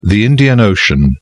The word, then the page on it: Indian Ocean: British English